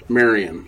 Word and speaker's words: Marion: Marion, like Maid Marion.